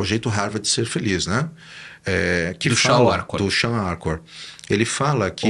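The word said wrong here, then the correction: Shawn Achor: na pronúncia correta, Shaw... – Shaw Arcor. – Acor. – É isso, é que nem Austin.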